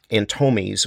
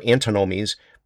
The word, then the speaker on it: antinomies: A paradox is okay, but there's actually a technical term that's called antinomy, and it's spelled A-N-T-I-N-O-M-I-E-S, antinomies, not antomies or antinomies, but antinomies.